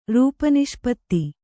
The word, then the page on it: ರೂಪನಿಷ್ಪತ್ತಿ: rūpa niṣpatti